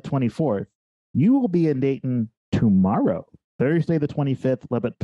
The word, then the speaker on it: Dayton: We pronounce it day, glottal stop, like we were English. – All right. – In. So Dayton, you don't hear the T.